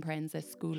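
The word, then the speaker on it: Proenza Schouler: He's designed handbags and accessories for everyone from Loewe, Smythson, Liberty London, Coach, and Prenza Schooler.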